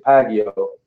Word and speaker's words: Paguio: So my last name is Pagio. Pagio. But I was exactly. Yeah, I wish it was Pacquiao.